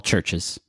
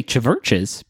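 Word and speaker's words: CHVRCHES: It's a band called Churches. And a lot of people see the name and they say Chiverches because there is a V in there, but the V is pronounced like a U, like you would see it like in written the old times.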